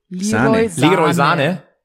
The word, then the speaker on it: Leroy Sané: Ihr sprecht das halt alle falsch aus. Ihr müsst es halt aussprechen wie Jogi Löw. – Leroy Sané.